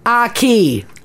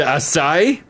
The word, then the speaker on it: acai: Number two, the food that Canadians mispronounce is acai. – Oh, yes. – How are we ordering it? Apparently, we're going into places and saying, we'll have the aki. – Aki, the acai? – Yeah, it's acai. – Yeah. – Not akai or aki.